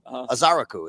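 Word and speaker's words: Ezeiruaku: Not Azaraku. Azaraku is how you say it.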